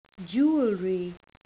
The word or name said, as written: jewellery